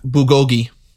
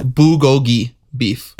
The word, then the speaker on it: bulgogi: Bulgogi? That beef? – Bulgogi. – Okay. – Yep. – How do you say that? – Bulgogi beef.